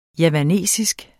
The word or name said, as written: JAVANSK